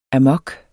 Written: amok

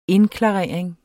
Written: indklarering

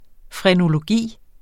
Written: frenologi

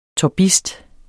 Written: TORBISTER

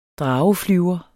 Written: drageflyver

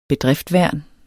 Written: bedriftværn

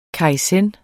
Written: kaizen

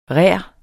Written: rær

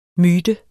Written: MYTEN

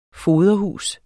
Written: foderhus

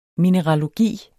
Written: mineralogi

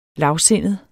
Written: lavsindet

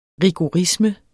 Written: rigorisme

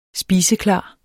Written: SPISEKLART

